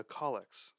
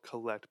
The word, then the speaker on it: collect: And then the collects. They're spelled collect, but it's pronounced collect because Anglicans are weird.